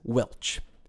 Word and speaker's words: Welsh: This usage is sometimes pronounced as Welch.